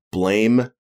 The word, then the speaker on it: Blame: there's a manga series called Blame. It's spelled Blame, but it's pronounced Blam.